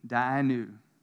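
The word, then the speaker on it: Dayenu: But it's D-A-Y-E-N-U, Dianu.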